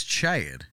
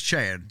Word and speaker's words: Chad: My name's Chad. – Chad. – He said he's got it. First try. – He said Shed. – No, you said Chad.